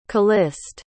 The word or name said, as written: caliste